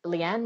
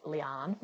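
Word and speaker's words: Liane: It turns out her name's pronounced Leanne Moriarty. Oh, I discovered after I said hi to her.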